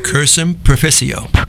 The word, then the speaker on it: Cursum Proficio: Cursum Proficio.